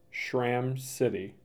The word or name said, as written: Schram City